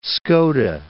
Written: Skoda

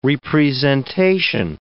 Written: re-presentation

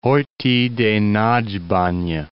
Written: Horthy de Nagybanya

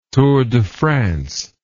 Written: Tour de France

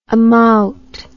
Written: amaut

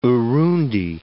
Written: Urundi

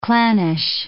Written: clannish

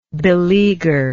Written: beleaguerer

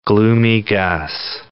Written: Gloomy Gus

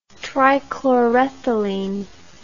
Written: trichlorethylene

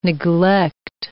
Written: neglect

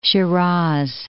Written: Shiraz

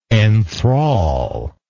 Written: enthralment

